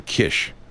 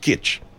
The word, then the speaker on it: Kiss: It's like my neighbor, my Hungarian landlord back in the 80s, Mr. Kish, who his name is spelled K-I-S-S. It was pronounced Kiss in America because that's what it looked like. But actually in Hungary, it's pronounced Kitsch.